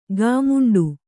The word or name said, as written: ಘಮೆಂಡಿ